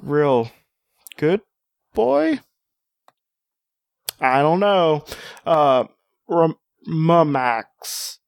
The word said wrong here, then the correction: Morax: Sorry, Morax.